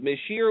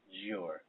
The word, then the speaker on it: Monsieur: Yeah, I do want to announce next week is part two, or part due, of A French Interruption with Monsieur Ooh-La-La. – Monsieur. It's Monsieur. Why can't anybody pronounce that right? – With Monsieur Ooh-La-La.